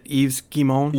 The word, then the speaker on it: Yves Guillemot: Is it Yves Guillemot? – Yeah, there you go. Perfect.